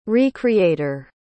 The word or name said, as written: re-creator